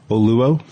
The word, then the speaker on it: Oluo: Oluo.